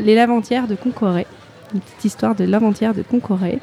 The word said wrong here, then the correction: lavandières: Et, les Lavantières. – Ouais, les Lavandières.